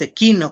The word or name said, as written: sekinoj